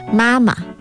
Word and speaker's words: 妈妈: I'm just saying the proper pronunciation is Mama.